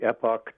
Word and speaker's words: Epoch Times: I subscribe to the Epoch Times. – Yep. – I pronounce it that way to, you know, just to make it clear that it's not like an epic poem.